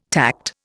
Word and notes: tact: Indian

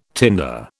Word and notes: tinder: British English

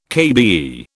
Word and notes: KBE: British English

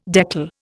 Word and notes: deckle: Indian

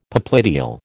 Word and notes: popliteal: American English

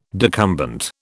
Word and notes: decumbent: British English

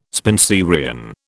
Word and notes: Spenserian: British English

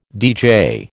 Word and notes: deejay: American English

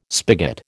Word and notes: spigot: American English

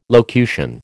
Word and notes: locution: American English

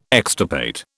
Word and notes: extirpate: British English

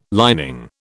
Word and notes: lining: British English

